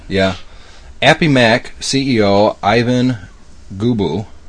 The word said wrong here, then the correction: Gabo: Is that how you pronounce Gabo. – It? – Gabo. – Gabo? Mm-hmm.